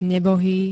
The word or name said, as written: nebohý